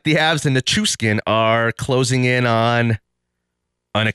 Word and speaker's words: Nichushkin: Did I say that right? Nichushkin. Is that Chooch or Nichushkin? – Nichushkin. – Nichushkin. Nichushkin. – Yeah. – Not skin, like Teddy Ruxkin.